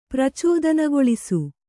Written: ಪ್ರಚೋದನಗೊಳಿಸು

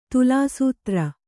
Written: ತುಲಾಸೂತ್ರ

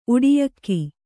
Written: ಉಡಿಯಕ್ಕಿ

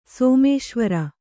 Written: ಸೋಮೇಶ್ವರ

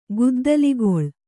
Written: ಗುದ್ದಲಿಗೊಳ್

